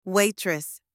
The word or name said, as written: waitress